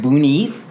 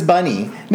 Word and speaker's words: Bunny: Let's say I have Bunny in my classroom, and I've never heard the word Bunny before, and so I'm pronouncing it Boonie. – Yeah. – I'm like, hey, Boonie, no, my name is Bunny.